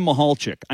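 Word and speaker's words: Michalczik: Jim Mihalchik. I know I have that one right. You know, it's a confusing spelling, but that's how you say it. Jim Mihalchik, he's the offensive line coach, one of the best in the country at what he does.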